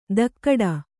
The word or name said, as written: ಧಕ್ಕಡ